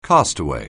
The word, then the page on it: castaway: anglais